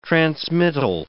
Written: transmittal

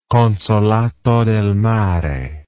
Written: consolato del mare

